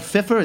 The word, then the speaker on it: Pfeiffer: Michelle Pfeiffer, is that how you pronounce her name? – Pfeiffer, I believe.